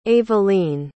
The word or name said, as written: evelien